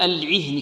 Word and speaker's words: العهن: والها هذه كذلك لابد من إيضاحها، العهن هكذا.